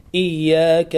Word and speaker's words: إياك: إياك نعبد وإياك نستعين، لابد أن تشدد الياء هكذا إياك، لا تقل إياك نعبد وإياك، إياك وإياك، لابد من تشديد الياء.